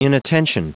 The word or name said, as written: inattention